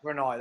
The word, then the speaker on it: Brunei: I couldn't say it right in my intro. – Oh, the deployment to Brunei, yeah. – Brunei, that's this it.